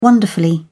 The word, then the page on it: wonderfully: British English